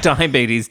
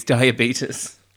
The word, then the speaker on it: diabetes: So not diabetes, diabetes. – Yeah, that's how the Americans say it, because the boy, Luke, says diabetes when she has fish paste, is fish paste high in sugar? – Salt?